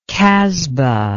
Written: kasbah

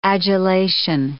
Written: adulation

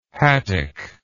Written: Hattic

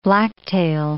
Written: blacktail

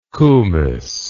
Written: koumis

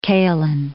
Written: kaolin

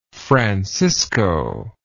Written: francisco

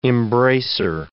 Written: embraceor

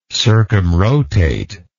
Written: circumrotate

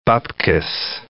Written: bubkes

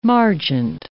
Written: margent

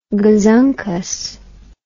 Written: gazunkus

